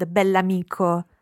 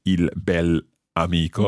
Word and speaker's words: bell'amico: And it's very important to pronounce the adjective and the noun as one word, bell'amico. – Bell'amico. – Uh-huh. – Okay, we don't say il bel amico.